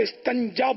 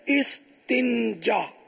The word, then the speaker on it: استنجا: استنجا کا طریقہ, عوام الناس اسے استنجا بولتے ہیں, صحیح تلفظ ہے استنجا.